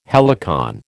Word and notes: helicon: American English

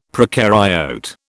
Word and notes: procaryote: British English

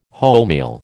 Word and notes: wholemeal: American English

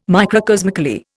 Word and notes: microcosmically: Indian

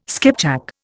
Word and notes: skipjack: Indian

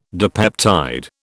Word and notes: dipeptide: British English

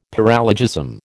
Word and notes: paralogism: American English

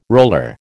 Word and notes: roller: American English

